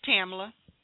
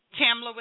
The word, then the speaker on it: Tamla: My name is Tamla. – Pamela. – Tamla with a T.